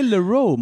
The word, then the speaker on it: Leroux: Det är Leroux man säger. För jag hörde någonstans Leroux, så det var det. För jag tänkte OU blir O. – That is actually the correct pronunciation.